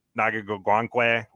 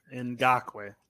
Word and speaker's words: Ngakoue: He brought Yannick Ngakoue with him. – Ngakoue.